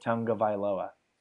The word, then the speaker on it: Tagovailoa: That's how you pronounce his name? – Tagovailoa? – Tagovailoa. Okay. There's no like N or yeah, there's no N in it, but there was like a video where someone asked him how to pronounce it, and he said Tagovailoa.